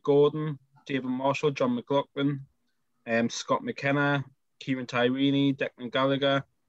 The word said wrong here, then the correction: Tierney: Did you say Tyrini? – How do you say it? Tierney. – Tierney. KT.